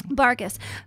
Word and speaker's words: Bargas: Did I say it right? – It's Bargas, honey. – Bargas.